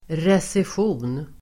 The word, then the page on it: recession: [resesj'o:n]